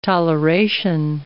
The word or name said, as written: toleration